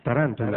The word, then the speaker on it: tarántula: Allí está la pequeña ciudad de Tarento, donde han sacado su nombre las taréntulas. – ¿Taréntula? – Tarántula.